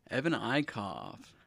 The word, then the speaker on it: Eickoff: Evan Eickoff. Hi, guys. – And that's how you say it. Eickoff. – Eickoff. – Eickoff. – You cough. – It's a COVID name.